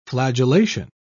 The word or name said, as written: flagellation